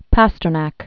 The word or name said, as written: Pasternak